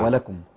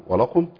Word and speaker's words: ولكم: ولكم. هنا ممكن القارئ يفخم الكاف فتنطق قاف. – نعم. ولكم. – ولكم.